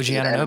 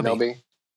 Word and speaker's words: Anunoby: Am I saying his name right? It's like it's like Obi Wan Kenobi. – Yeah, OG Anunoby. Anunoby.